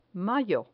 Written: mayo

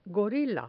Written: gorila

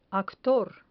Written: actor

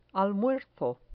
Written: almuerzo

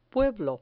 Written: pueblo

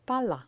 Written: pala

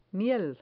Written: miel

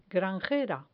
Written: granjera

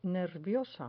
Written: nerviosa

